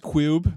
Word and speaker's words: Qube: Am I supposed to say Q-U-B-E? Or just it's pronounced Cube.